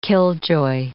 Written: killjoy

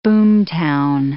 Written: boomtown

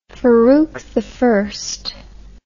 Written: faruki

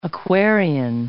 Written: Aquarian

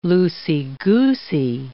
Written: loosey-goosey

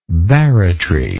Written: barretry

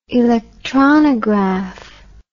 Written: electronography

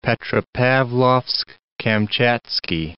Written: Petropavlovsk-Kamchatskiy

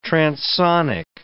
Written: transsonic

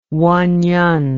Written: wenyen

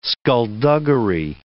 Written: skullduggery

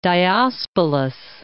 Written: Diospolis

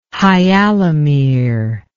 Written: hyalomere

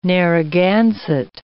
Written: Narragansett

